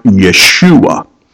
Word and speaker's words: Yeshua: If I were to try to pronounce it in Hebrew, it would sound something like this. It would sound like Yeshua. And so we anglicize that and make that Jesus.